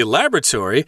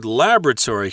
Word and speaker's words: laboratory: I should mention the word laboratory here. That's the American pronunciation. And I believe, as an Englishman, you say laboratory. – That's right, laboratory, or, or just lab. I think most people will say lab for short in, in mm.